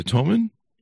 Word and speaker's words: Talmud: Now, you mentioned the, and hopefully I pronounced this correctly, the Talmud.